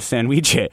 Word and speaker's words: sandwiche: Listener Rebecca writes in to say, my dad, a Mexican immigrant, would make what he called sandwiche. It rhymes with ceviche, but all similarities end there.